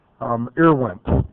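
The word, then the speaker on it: IRWMP: IRWIMP is the way that you pronounce it, acronym.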